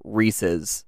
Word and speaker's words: Reese's: They wouldn't choose the word pieces if it didn't rhyme with Reese's.